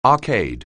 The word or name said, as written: arcade